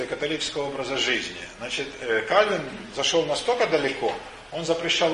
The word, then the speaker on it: Кальвин: Ну и как сейчас говорят, Кальвин. Правильно говорить, на самом деле, даже Кальвен по-французски, поскольку он был франкоговорящий швейцарец, жил он в Женеве.